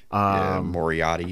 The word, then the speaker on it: Moriarty: I think he went to a 15-20 match with Lee Moriarty. Is that how you pronounce it? – Moriarty.